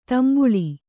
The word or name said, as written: ತಂಬಳಿ